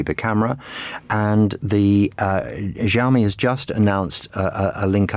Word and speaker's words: Xiaomi: Yes, this is Xiaomi, as it's pronounced, which has been making phones for a while now.